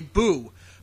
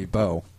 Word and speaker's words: beau: I don't remember how it's phonetically supposed to be, but it's not boo as in, like, oh, the ghost said boo. – Well, it would be beau.